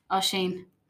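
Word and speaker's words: Oisín: So this one's pronounced O-I-S-I-N, and I'm going to say Owen. – No. – No. – O'Sheen. – O'Sheen? – O'Sheen, all right. – Yeah. – And the final one we have is C-I-L-L-I-A-N.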